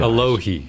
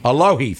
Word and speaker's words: Alohi: Aloe Gilman, and if I'm mispronouncing his first name, which I probably am, I apologize. – Alohi. – Alohi, thank you.